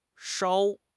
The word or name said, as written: 梢